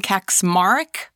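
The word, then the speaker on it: Kacsmaryk: Kaczmarek, I believe is how you pronounce his last name, Kaczmarek.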